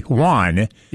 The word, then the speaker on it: Joan: Some friends, neighbors, and advocacy groups spell his name J-O-A-N, which would be Juan.